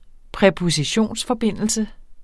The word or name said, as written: præpositionsforbindelse